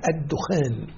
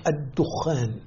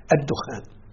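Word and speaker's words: الدُّخَان: يوم تأتي السماء بدخان مبين في هذه السورة، فاللغة القرآنية، النطق القرآني الصحيح لهذه الكلمة الدخان. وبالمناسبة فتشنا في الروايات المتواترة والشواذ فلم نجد فيها لفظ الدخان، بل هي الدخان.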